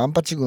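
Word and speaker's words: 安八: まあ本当にね、あの当時僕らがね、もう僕らは僕なんかは岐阜のね、アンパチ郡アンパチ町っていうね、よく輝きアンパチの話がよく出てきますが、最近でいわゆるその岐阜県のアンパチ郡アンパチ町っていうね、安いに八と書いてですね、アンパチと読みますがね、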